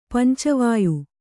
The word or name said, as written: ಪಂಚವಾಯು